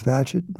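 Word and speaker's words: Bagehot: So I learned how to pronounce his name, as in Gadget, no small thing. I saw that the prior biography of Badgett had been produced as long ago as the 1950s.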